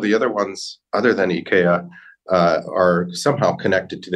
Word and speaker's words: IKEA: We have uh, Volodymyr Zelensky on there, but others are more evergreen, uh, like IKEA, or actually, I should probably say IKEA, I think is the, is the correct pronunciation.